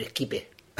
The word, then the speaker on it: Skype: Y a través de Skype o Skype O esquipe, ¿cómo se dice? – A mí me gusta más decir esquipe. Esquipe, se dice más. Esquipe, como, se escribe, ¿eh?